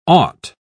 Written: aught

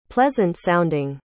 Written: pleasant-sounding

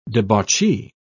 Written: debauchee

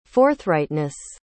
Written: forthrightness

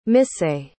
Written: misae

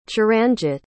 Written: charanjit

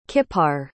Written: kippar

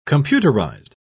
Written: computerized

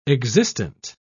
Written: existent

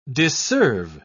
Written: disserve

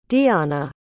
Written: diahna

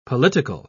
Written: political

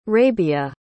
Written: rabia

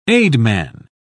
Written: aidman

